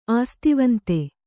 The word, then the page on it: ಆಸ್ತಿವಂತೆ: āstivante